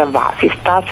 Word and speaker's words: vasistas: Ecco, questo è un nome molto curioso perché effettivamente se voi andate a vedere anche su internet apertura vasistas è scritto esattamente come lo stiamo pronunciando.